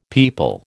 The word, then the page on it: peepul: American English